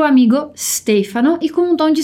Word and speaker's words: Stefano: A gente não diz Stefano, é Stefano.